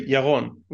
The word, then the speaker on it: Yaron: So basically in Hebrew, you just say Yaron, which to me sounds pretty easy and straightforward, but yeah.